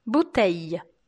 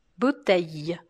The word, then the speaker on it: bouteille: Muitas vezes as pessoas acham que tem que falar bouteille pronunciando o L marcado. Mas não é assim. Eu sempre indico que você tente fazer um I comprido, ou ainda que você interrompa um som no meio.